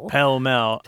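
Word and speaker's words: Pall Mall: Yeah, and a weird pronunciation. All I know about that is that it's called the vulgar pronunciation. – Oh. – Pell-mell.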